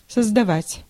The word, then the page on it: invent: /ɪnˈvɛnt/